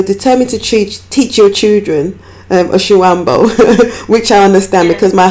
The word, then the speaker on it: Oshiwambo: Your language is Oshiwambo. Did I pronounce that correctly? – Yes.